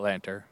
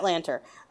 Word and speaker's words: Lanter: I believe it's Matt Lanter. – Yes, Matt Lanter.